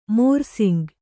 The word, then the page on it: ಮೋರ್ಸಿಂಗ್: mōrsing